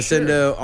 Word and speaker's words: Asendo: That's spelled very much like Mo. Instead of an M, it's A-S-E-N-D. And then we share an O. There you go. That's how it's like Mo. So for Ascendo on Spotify.